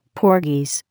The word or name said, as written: Porgies